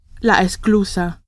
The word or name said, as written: esclusa